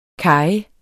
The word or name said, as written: kaje